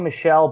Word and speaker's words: Michel: And then Sony Michel or Michael. What's his pronounced? Michel? – It's Sony Michel, but there's one running back we're forgetting to talk about here.